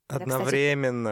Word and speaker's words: одновременно: Все, короче, мы с тобой кончили вдвоем одновременно. – Одновременно. – Это, кстати, одновременно. Это, кстати, тоже очень важно. Не только исправлять ударение своему любимому человеку в словах, но, но и, и кончать одновременно.